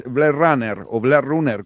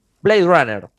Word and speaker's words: Blade Runner: Otra opción de películas que aparecieron con una nueva saga o se transformaron en saga y volvieron a lo grande es Blade Runner, ¿cómo se dice? – Blade Runner.